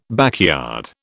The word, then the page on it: backyard: British English